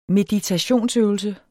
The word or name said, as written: meditationsøvelse